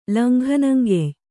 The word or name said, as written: ಲಂಘನಂಗೆಯ್